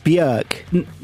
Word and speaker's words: Björk: It's pronounced Björk. – No, that's no good because that doesn't rhyme with orc.